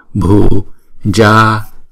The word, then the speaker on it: Bhujaya: Chữ bhu này chúng ta phải đọc âm bậc Bhujaya.